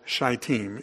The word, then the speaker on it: Shittim: Also, another point just to note is that the chapter opens with Israel being camped at, as one preacher said, the place is called Shittim. It's a long I.